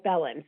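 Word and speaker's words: Belin: even though my name only has one L in it, it is pronounced as if there were two L's, like Bellin.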